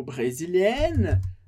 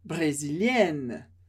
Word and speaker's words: brésilienne: Uma leve diferença na escrita que muda a pronúncia. Brésilien e feminino, brésilienne.